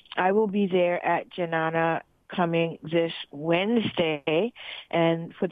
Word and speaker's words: Jnana: That's spelled J-N-A-N-A, Janana Yoga, 97 Bond Street in Brooklyn, 11217.